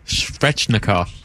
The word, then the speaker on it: Svechnikov: Svechnikov. – Thank you. – Svechnikov. – Svechnikov.